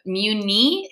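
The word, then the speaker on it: Mui Ne: I know that I'm not going to pronounce this properly, but Muni, it's M-U-I-N-E.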